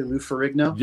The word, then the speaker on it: Ferrigno: Lou Ferrigno? – Yeah, I met him once.